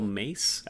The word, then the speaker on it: Mais: I need to mention, too, the voice of the Evil Bong that we've heard periodically is played by Michelle Mace. I think how you say her name, M-A-I-S.